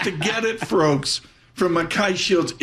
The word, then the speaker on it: Mackay Shields: Eric Kaczynski joins us with Mackay Shields. Am I pronouncing that right? – You got it. – Mackay nailed it.